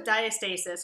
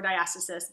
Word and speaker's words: diastasis: So, I will say that probably 99 to 100% of pregnant people have some diastasis or diastasis, both pronunciations are correct, during their pregnancy.